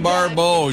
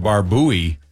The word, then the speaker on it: Barbeau: So, Adrienne Barbeau, she was Maud's daughter. And some people pronounce it Barbouy. – Right. – Adrienne Barbouy, but it's Barbeau.